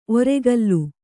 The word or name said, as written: ಒರ್ಕಲು